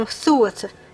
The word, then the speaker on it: Tuatha: A pronúncia é um Thuatha.